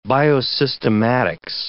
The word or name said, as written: biosystematics